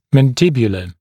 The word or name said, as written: mandibular